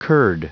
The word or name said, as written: curd